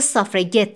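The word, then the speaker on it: suffragette: از لفظ سافرجت به قصد توهین استفاده میکنه اما امیلی پنکرست با آغوش باز از این واژه استقبال میکنه و اون رو سافرگت تلفظ میکنه چون اونا رأی رو خواهند گرفت تو پرانتز بگم بازی با حرف جی در فعل گت به معنای گرفتن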